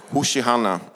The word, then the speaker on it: Hosianna: Hosianna, det är ju det hebreiska ordet, eh, ja, man uttalar det typ Hoshi, Hoshihanna, som betyder rädda oss.